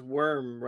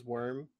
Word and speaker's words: Wyrm: Well, it makes sense because it's Worm, right? W-Y-R-M is Worm.